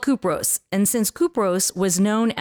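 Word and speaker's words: Kupros (Κύπρος): mined it on the island of Cyprus, which is not pronounced Cyprus. It's pronounced Kupros.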